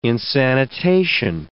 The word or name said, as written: insanitation